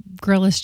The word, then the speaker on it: Grylls: Grylls. Just like what you would do outside. – Is that how you say it? – Bear Grylls. – With a Y.